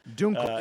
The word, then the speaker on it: Dunkel: Dunkel. – It's pronounced Dunkel. – Dunkel. It's pronounced Dunkel. – At least traditional. – Well, it's Dunkel.